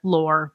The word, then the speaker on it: L'Or: I'm from Canada, so this should be easy, but my French accent is terrible. It's L'Or. L'Or.